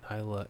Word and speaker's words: Isla: So the S is silent. Isla, is this Isla? – That's how I'm saying it. – Isla? – Sure, you can say it that way too.